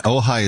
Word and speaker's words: Ojai: And Ojai is spelled O-J-A-I. So friends, if you're trying to figure it out, Calvary Ojai, O-J-A-I, is how you get there.